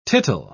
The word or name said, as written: tittle